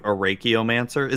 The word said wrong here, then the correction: Archaeomancer: Is that not how you say it? Arachiomancer? How do you pronounce the name? Oh, shit. – Archaeomancer? – Is it Archaeomancer? – Yeah. – It's not Arachio? Oh, Jesus.